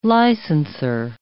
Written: licensor

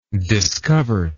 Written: discovert

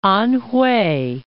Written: Anhwei

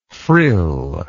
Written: frilled

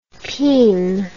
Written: pein

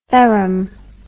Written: ferrum

